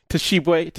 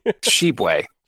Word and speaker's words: Tshiebwe: Tshiebwe. – Tshiebwe, I think the T is silent.